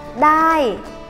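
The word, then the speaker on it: đai (ได้): được được thì tiếng thái đọc là đai đai ở trên bảng dương ghi là đai nhưng mà mình âm điệu cho giống người thái thì là đai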